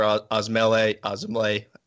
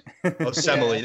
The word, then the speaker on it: Osemele: They did trade for Osemele. Goddamn, I butchered that. – Osemele, there we go.